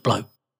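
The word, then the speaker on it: blót: The main rites celebrated in heathenry are called blot, pronounced blow, and symbol, pronounced sumbul.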